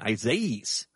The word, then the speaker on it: Isaias: The E at the end of it is the big pronunciation, Isaias. Isaias. Okay. That sounds like an AIA in the middle of it.